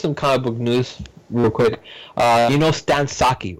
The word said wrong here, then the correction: Sakai: right? – Sakai. – Sakai is how you pronounce it?